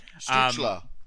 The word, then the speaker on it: Stützle: Stützle, I'm not going to try to do the German pronunciation of that. – Stützle.